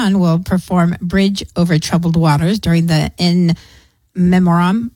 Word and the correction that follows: Memoriam: Memoriam. – I always say that wrong.